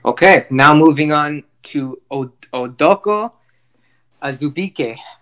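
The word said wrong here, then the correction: Udoka Azubuike: Okay, we butchered the guy's name. Sorry. – Okay, hold on. – Try it again. – Okay, bro. Udoka Azubike.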